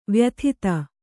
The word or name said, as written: ವ್ಯಥಿತ